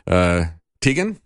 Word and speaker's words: Teigen: Chrissy Teigen, I think is the way you pronounce her name.